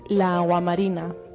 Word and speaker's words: aguamarina: Pero hay palabras como aguamarina, que no tiene eh, la sílaba fuerte en la A, sí. – que es la aguamarina.